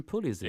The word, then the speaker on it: Pulisic: Christian Pulisic.